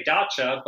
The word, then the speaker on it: dacha: And some really unusual attractions, like the Russian, is it a dacha? Am I saying it correctly? – Yeah, well, hey, that's not my world of things, but I say dacha.